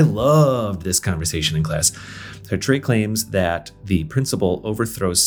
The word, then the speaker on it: Sartre: I finally figured out by the end of the semester that it's pronounced Sartre, but I'm going to leave this in because this is just where I was at the time.